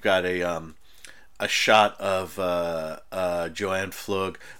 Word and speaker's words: Pflug: Oh, I know I call her Paflug. I look at it this way. Her name is spelled P-F-L-U-G, and we've been told over and over again that it is Flug or Flug.